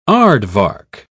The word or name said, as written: aardvark